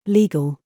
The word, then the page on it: gig: British English